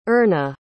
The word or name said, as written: Erna